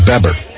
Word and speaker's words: Bebber: And I hope I'm saying that right. B-E-B-B-E-R. Would you say, it wouldn't be B-B, there's only one E, so it'd be Beber. – Yeah, I'd say Beber.